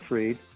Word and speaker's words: Fried: Some people pronounce it Freed.